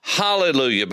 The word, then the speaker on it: hallelujah: As a matter of fact, if you open your Greek New Testament and read the Koine Greek, there's a rough breather with alleluia, which means it should be pronounced hallelujah, because hallel is the word for praise.